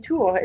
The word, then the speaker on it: Tours: Like, you living in Tours, it's like, very nice pronunciation.